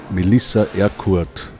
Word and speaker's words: Melissa Erkurt: Herzlich willkommen, Gast in der heutigen Sendung ist Melissa Erkurt.